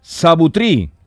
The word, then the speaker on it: Sabutri: Sabutri. Luna. Lo digo bien, ¿no? Sí, sí, perfecto.